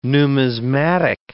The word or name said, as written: numismatic